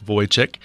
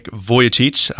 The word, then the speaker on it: Vujicic: That's exactly how you say it. A lot of us say Nick Vujicic, but it's Nick Vujicic. I have been corrected by Nick himself in regards to his last name,